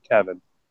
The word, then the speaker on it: Cavan: It's spelled Cavan. I'm pretty sure they pronounce it Kevin.